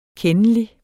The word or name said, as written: kendelig